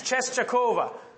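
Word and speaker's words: Częstochowa: Now, if you saw how that was written, you would be impressed by how I pronounce that. It's Czeskowa, okay?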